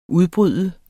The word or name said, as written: udbryde